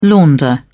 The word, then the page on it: launder: British English